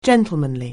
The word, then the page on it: gentlemanly: anglais